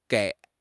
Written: gei3

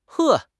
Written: 呵

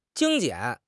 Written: 精简

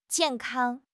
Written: jian4 kang1

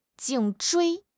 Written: 颈椎

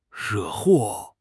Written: 惹祸

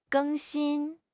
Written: 更新

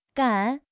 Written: gan3

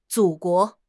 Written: 祖国

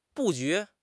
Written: 布局

